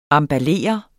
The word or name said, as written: EMBALLER